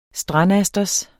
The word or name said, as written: strandasters